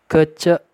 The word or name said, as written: Kecek